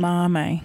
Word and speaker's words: edamame: He used endami. Is that how you pronounce it? Beans and not broad beans. Thank you. You're looking at me. – I call them edamame.